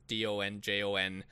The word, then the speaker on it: donjon: Donjon is still used, just not commonly, but it's not completely forgotten or archaic. And for the record, it's spelled like the name Don and John, Donjon, and it is the origin of the word dungeon, but it was originally a donjon, and I like that better.